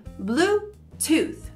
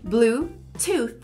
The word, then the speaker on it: Bluetooth: E a forma correta, da forma que você falaria se estivesse lá, ou da forma que você escutaria, seria Bluetooth. Repeat with me. Bluetooth. Você faz o th no final.